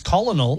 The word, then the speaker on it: colonel: If you're hearing this for the first time, you might know the word colonel, but sometimes you've spent your life reading it and pronouncing it in your mind as colonel. – Yeah. – That's actually colonel.